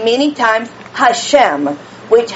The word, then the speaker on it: Hashem: I believe it's Hashem. Hashem is how they pronounce it.